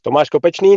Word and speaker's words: Tomáš Kopečný: I haven't heard who it will be, but it's most likely to be, here comes my Czech pronunciation again, Tomáš Kopečný. – Tomáš Kopečný, that's exactly a perfect pronunciation.